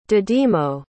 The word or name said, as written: dídimo